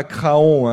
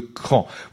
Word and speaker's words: Craon: Alors attention, on ne dit pas Craon, hein, comme je l'entends parfois, hein, quand on parle de Cran au niveau national, parfois les présentateurs font des erreurs de prononciation, ça s'écrit C-R-A-O-N, c'est bien la ville de Cran.